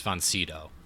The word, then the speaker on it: von Sydow: It's von Sydow.